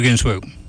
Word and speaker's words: Ginsburg: Because isn't Ruth, is it Ginsburg? Is that how you say her name? – Ruth Bader Ginsburg.